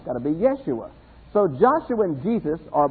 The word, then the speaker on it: Yeshua: As you know, there are no J's in Hebrew. So Jesus is not really the way the name was pronounced. All J's in Hebrew are Y's. So the way to probably pronounce Jesus' name as he would have recognized it is Yeshua.